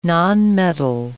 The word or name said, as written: nonmetal